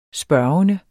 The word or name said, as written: spørgende